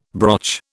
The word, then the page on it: broch: British English